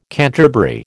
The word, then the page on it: canterbury: American English